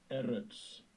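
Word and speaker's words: Eretz (אֶרֶץ): Eretz.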